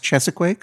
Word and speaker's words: Cheesequake: It is spelled Cheesequake. – It's spelled Cheesequake like just something you can get in the Guy Fieri restaurant. – But you're telling me that it's actually pronounced Chesequake? – That's what I was told.